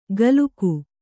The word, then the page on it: ಗಲುಕು: Kannada